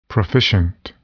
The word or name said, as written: proficient